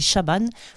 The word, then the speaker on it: Šaban: c'est un S avec un petit euh, V dessus, hein, euh, façon serbe. S-A-B-A-N, donc ça se dit Chaban. Plus loin, S-H-U-K-A-R, Choukar, Shaban Choukar.